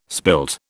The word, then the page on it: spilt: British English